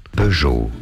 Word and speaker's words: Peugeot: Peugeot, silent T there. In English, Peugeot, Peugeot, from French, Peugeot.